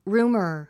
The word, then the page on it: rumor: rúːmə r; ルーマァ